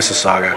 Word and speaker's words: Mississauga: It's actually Mississauga.